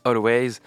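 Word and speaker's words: Alvvays: Always avec deux V, c'est sûrement plus facile pour être référencé sur l'internet mondial. Je pense que ça se prononce Always.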